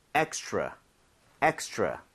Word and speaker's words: extra: 여러분들이 r 발음을 혀를 제대로 당겨서 하고 있다면 extra처럼 치읓 비슷하게 소리가 날 겁니다. 자, 입 모양을 모으고 한번 try 해보도록 할게요. 두 번 갑니다. Extra, extra.